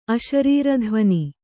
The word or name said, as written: ಅಶರೀರಧ್ವನಿ